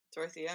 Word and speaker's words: Dorothea: Dorothea, Dorothea, am I saying that right? – Yeah.